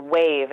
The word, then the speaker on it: Weyv: I really am enjoying this app called Wave. It's spelled W-E-Y-V, and it packs music and magazines, children's ebooks, and soon podcasts all into one app.